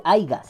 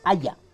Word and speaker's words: haya: Mm, no se dice aiga, se dice haya.